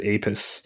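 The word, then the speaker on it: Apess: I could have gone different ways, but I still say Apis, and I know there are others who would go a different route with that. – Yeah, most scholars that I've heard pronounce it Apis.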